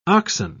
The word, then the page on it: oxen: British English